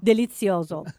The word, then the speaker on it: delizioso: Delizioso.